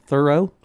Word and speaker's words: Thoreau: Joel Mann, did you know that Thoreau is pronounced Thoreau? – No, I did not know that, Judge.